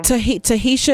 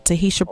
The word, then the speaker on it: Tahisha: Tahisha is spelled T-A-H-I-S, as in Sam, H-A, and my last name is Brooks, Tahisha Brooks.